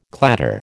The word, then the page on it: clatter: American English